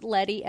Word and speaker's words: Letty: First, and to get it out of the way, my name is pronounced Letty, as in spaghetti.